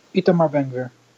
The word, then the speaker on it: Itamar Ben-Gvir: Itamar Ben-Gvir, how do you say that correctly? – Itamar Ben-Gvir. – Close enough.